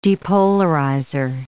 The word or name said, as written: depolarizer